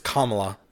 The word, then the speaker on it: Kamala: Yeah, it's it's Kamala, like our vice president. – I didn't know how to say her name either. I've always just read it. – Well, it's spelled like for us, I guess, in American English, I guess, you would put like, you see it and you think like Pamela, but there's a K, so you would just go Kamala, but it's Kamala.